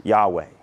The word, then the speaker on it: Yahweh: All of those are good translations of that particular name, which we pronounce Yahweh.